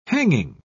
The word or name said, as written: hanging